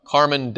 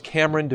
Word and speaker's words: Kameron: I didn't want them coming up, yes, Mr. Carmen DeVasher, or what? No, it's Kameron DeVasher. Say, Mr. DeVasher, your table is ready, right back here. So say it right. Put the long A over there.